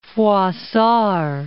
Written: Froissart

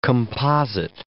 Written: composite

